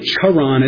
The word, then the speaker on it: Haran: I'm going to probably end up pronouncing it Haran, but it's really Charan. It's got that sound in the original language.